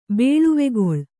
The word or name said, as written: ಬೇಳುವೆಗೊಳ್